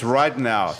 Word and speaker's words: Ridenour: C-A-I-T-L-Y-N. – I think and then, Ridenour. – It's Ridenour. Thanks.